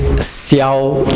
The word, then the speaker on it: Siow: My name is Siow Chai Sing. It's spelled S-I-O-W.